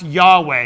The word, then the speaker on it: Yahweh: Now, when you look at that, it comes from the Hebrew word that is spelled without the vowels, because there are no vowels in Hebrew, YHWH, which we best understand is pronounced Yahweh, because when you put in the vowels, it looks like that.